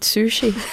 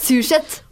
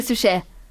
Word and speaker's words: Suchet: Jeg tror du uttalte navnet feil. Jeg tror det er David Sushi. det, nei, det er Sushet. – Eller Sushé. Ja, mm. – ja. ikke sushi.